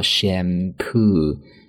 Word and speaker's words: shampoo: 那除了这样的一个词组之外呢，我们其他的一些相关和头发相关的一些单词啊，比如说洗发水，很简单，我们叫做 shampoo，shampoo，两个音节单词重音在第二个音节上，读作 shampoo。